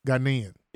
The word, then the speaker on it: Ghanaian: So did, now, how did you identify with those thugs, even though, okay, you're Ghanaian, you're, how would you say it? – Ghanaian.